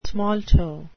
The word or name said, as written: smalto